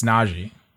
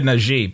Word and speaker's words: Nagy: It's Nagy. – Najee. – I always said Najee, but it's not.